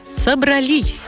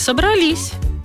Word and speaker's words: собрались: Правильно говорить «собрались». А еще «собралась», «собралось». Вот давайте разберемся. Он собрался в поход, а вы? А мы собрались. И на праздник мы тоже собрались. И она собралась. Ударение видит, что слово заканчивается на «с» и убегает туда, в конец слова.